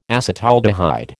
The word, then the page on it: acetaldehyde: American English